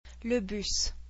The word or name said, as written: Le bus